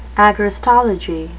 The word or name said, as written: agrostology